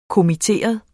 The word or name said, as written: kommitteret